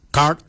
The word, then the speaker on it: gaad: Gard is spelled G-A-A-D.